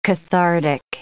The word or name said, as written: cathartic